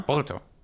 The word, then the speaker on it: Boruto: aber man spricht es am ehesten wie Bolto aus. – Ja. Bolto ja. was halt Bolt ist auf Englisch. Also das englische Bolt, japanisch ausgesprochen ist Bolto und wenn man das ausschreibt, steht da Boruto.